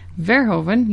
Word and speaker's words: Verhoeven: Verhoeven, yep, that's how you say his name.